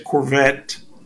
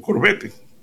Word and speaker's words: Corvette: En inglés se dice Corvette, pero en español nosotros decimos Corvette, porque así es como se escribe, ¿no? C-O-R. Vette, Corvette.